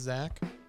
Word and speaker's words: Zack: My name is Zach, like Pack. That's how you spell it. No? Oh. – Z-A-C-K-P-A-C-K. Yes. Podcast.